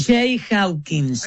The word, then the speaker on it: Jay Hawkins: Jay Hawkins.